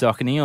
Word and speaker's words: Saucony: Yeah, no, it is definitely pronounced Saucony, although I've heard a lot Saucony? – Of different iterations. Saucony is definitely how it's more commonly said.